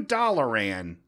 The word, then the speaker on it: Dalaran: I started watching that while farming for sightless eyes in the underbelly sewers of New Dalaran. Did I say that right? – Pretty much.